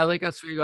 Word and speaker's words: Oswego: Yeah, Lake Oswego.